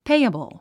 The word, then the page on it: payable: péiəbl; ペイエボォ